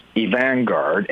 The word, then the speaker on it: Avant-Garde: Well, it's the way we pronounce it is evangard, and it's the conflation of two words. And one is evangelical, which we define as loyal to the gospel of Jesus Christ. And then vanguard, which is a foremost part of an advancing army, if you will, or a naval force.